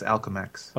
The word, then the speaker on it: Alchemax: I think it's Alchemax. – Okay, Alchemax. – Like as an alchemist.